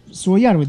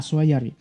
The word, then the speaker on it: Суоярви: Ну, Суоярви, да, правильно говорить, я помню. Сейчас, секундочку. Да, Суоярви.